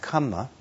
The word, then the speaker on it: kamma: In Pali, the word's pronounced kamma.